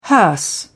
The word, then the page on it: hearse: British English